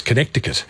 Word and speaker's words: Connecticut: US state of Connecticut actually is got a C in it. So if you're doing it phonetically, it's Connecticut.